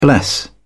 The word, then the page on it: bless: British English